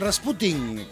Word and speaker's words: Rasputin: Así que don Rasputin, que así se pronunciaba en su Rusia natal.